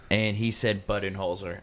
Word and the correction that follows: Budenholzer: And I was like, come on, bro. It's Mike Budenholzer.